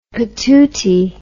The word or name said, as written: patootie